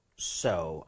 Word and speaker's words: also: 자, 이거 발음할 때 여기는 all이 아니라 뭘까요? – 아, 약간 입을 벌려줘야 되나 exactly. 봐요. Also. 앞쪽에는 이렇게 조금만 벌려주세요. 두 번 갑니다.